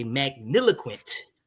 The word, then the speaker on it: magniloquent: Magniloquent. – Spell it. – Magniloquent is MAGNILOQUENT. – You said magniloquent? – Magniloquent. – Oh, my fault.